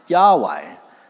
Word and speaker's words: Yahweh: It's YHWH. And we think it's pronounced Yahweh.